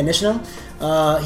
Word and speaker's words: Nishino: Hideki Nishino? Yeah, Nishino.